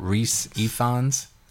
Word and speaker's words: Rhys Ifans: Rhys Ifans? – Yes, that's right.